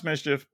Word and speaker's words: MSCHF: And it's pronounced Mischief, but it's spelled M-S-C-H-F. So basically no vowels.